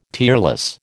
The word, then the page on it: tearless: American English